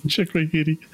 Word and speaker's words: Chicoutimi: Is anybody saying that right? Chiquita? Taquito? – I think it's Chicoutimi. – Chicoutimi. – Yeah.